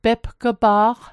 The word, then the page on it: pepg'pa'q: Mi'gmaq